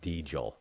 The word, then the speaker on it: Deagel: So I'm calling it what it's spelled like, Deagle.